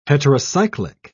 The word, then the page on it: heterocyclic: British English